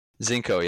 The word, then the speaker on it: Zinco: And yeah, we've got Zinco Green Roofs, and it's pronounced Zinco. Is that correct? – Zinco, yep.